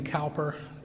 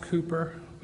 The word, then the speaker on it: Cowper: Most people pronounce it William Cowper because it's spelled C-O-W-P-E-R, but it's pronounced Cooper, William Cooper.